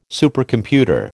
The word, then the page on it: Supercomputer: American English